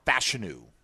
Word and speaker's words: Fashanu: So the Jags, if they had stayed put, could have had Olufashanu, or Fashanu, as I've heard it's pronounced, the Penn State tackle.